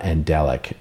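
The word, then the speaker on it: Andjelic: I'm going to say her last name is pronounced because it's A-N-D-J-E-L-I-C. Anna Andelic.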